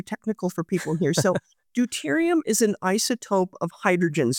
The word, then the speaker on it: deuterium: Was it deuterium? Is that how you say it? Deuterium abundances? – Deuterium, yeah.